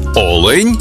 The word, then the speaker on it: олень: однині і в множині наголос падає на перший склад – олень та олені.